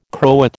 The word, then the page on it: Croat: American English